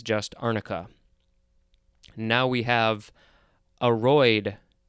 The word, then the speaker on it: aroid: That's A-R-U-M family. And aroid is also a noun. But no, it looks like it's more pronounced aroid. The first syllable is emphasized.